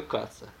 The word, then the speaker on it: Kac: и он писал реферат какой-то статьи Марка Каца. – Yeah. – Кац, как известно, пишется K-A-C. Марк Кац. И Миша со спущенным знанием английского языка, но не знание самого Каца, написал, что он его зовут как.